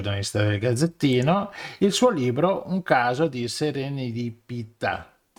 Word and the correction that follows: serendipità: Scusa se l'ho detto giusto. – Serendipità.